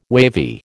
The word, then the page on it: wavy: American English